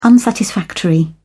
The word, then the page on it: unsatisfactory: British English